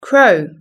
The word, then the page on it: crow: British English